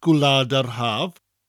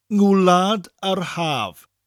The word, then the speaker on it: Gwlad yr Haf: To mutate Gwlad yr Haf, we just add an N in front of it. Gwlad yr Haf. Just a very light N sound at the beginning of the word.